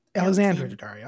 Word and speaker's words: Alexandra Daddario: Is it Alexandra? Alexandra? What is it? Alexandria Daddario? Mm. – Alexandra Daddario. I said it right the first time.